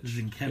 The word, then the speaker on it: Sienkiewicz: You pointed me towards that Demon Bear saga with Bill Sienkiewicz. I don't know if I'm saying that right. – Zinkevich. Yeah, I just recently learned the pronunciation of it myself.